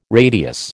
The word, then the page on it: radius: American English